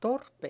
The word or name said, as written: torpe